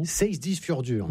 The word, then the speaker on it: Seyðisfjörður: Seyðisfjörður.